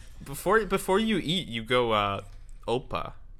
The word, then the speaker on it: Opa: It's opa. – Opa. – Emphasis is on syllable opa. – One. – On the O, okay, yeah.